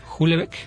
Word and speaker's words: Houellebecq: Houellebecq. – Ajá. Se escribe H-O-U, ¿no? Uh-huh. H-E, doble L, ajá. E-B-E-Q, Houellebecq.